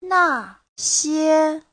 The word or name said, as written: na4xie1